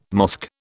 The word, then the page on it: mosque: British English